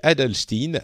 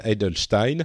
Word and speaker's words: Adelstein: C'est euh, un livre de Jake Adelstein, Edelstein, en fonction de la manière dont vous voulez prononcer son nom.